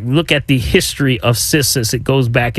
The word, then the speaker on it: Cissus: Cissus, okay, it's spelled C-I-S-S-U-S. Okay, Cissus.